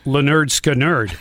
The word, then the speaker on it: Lynyrd Skynyrd: Lynyrd Skynyrd. The old PE teacher. – Yeah. – But then they pronounced it Leonard Skinnerd.